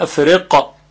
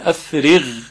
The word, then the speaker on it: افرغ: ربنا افرغ علینا، صدای اون با قاف فرق میکنه، قاف اینطوری میشه افرق که این غلطه، افرغ، افرغ.